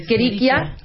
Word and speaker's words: Escherichia: Es que no sé cómo se Escherichia. pronuncia. Escherichia.